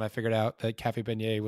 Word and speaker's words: beignet: Beignet, not Bignet or something like that, because there are several people that have or as the FLG staff judge pronounced it, bidet.